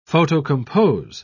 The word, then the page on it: photocompose: British English